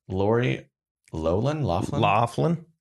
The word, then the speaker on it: Lori Loughlin: Her name is Lori Loughlin. That's how you say it.